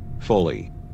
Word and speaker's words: Foley: Foley. – How do you spell that? – F-O-L-E-Y.